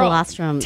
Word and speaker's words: colostrum: that I heard it's liquid gold with the colostrum. Am I saying that correctly? – Yes, girl, colostrum.